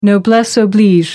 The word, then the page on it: noblesse oblige: American English